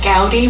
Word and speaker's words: Gowdy: That's Gowdy, rhymes with howdy.